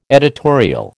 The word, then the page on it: editorial: American English